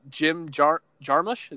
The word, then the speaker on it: Jim Jarmusch: I guess the director here, Jim Jarmusch, is that how you say his name? – Yeah.